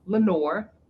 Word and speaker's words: LeNoir: So the L in my name is LeNoir, is the way that I pronounce it here in the US. It is French. And even I don't know how to pronounce it French. I went, you know, to France and I said LeNoir, and they were like, that's not how you say that. But it is two words. So it's L-E-V and N-O-I-R, black.